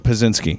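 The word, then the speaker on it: Pazsitzky: Christina Patsiki. Am I saying that right? – Pazinski. – Pazinski. Thank you. When I get into those Polish names, I just bail out halfway through.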